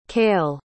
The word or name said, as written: cale